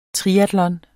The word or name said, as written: triatlon